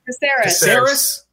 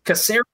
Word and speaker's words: Caceres: Caceres. – Caceres, isn't it? – Yeah, soft C.